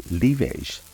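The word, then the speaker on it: livèche: Lavage, or livage, as it is pronounced in French, is a perennial plant that is part of the Apiaceae family, or parsley family, which includes parsley, of course, dill, and carrot.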